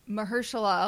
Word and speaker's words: Mahershala: Mahershala Ali.